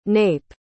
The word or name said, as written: knape